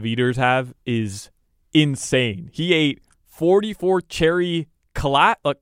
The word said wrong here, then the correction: kolaches: Is that how you pronounce it? Kolaches. – Oh, those kolachi. That's kolaches.